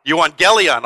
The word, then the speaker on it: euangelion: Biblically speaking, gospel is the translation of the Greek noun euangelion, I'm going to need help with the pronunciation. Euangelion. Oh, I made the G soft.